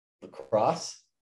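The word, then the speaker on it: La Crosse: How do you pronounce it? – Lacrosse. – Good. Okay. I'm glad we're on the same page.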